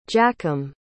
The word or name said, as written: jakim